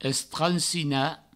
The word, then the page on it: estransinar: Provençal; estransina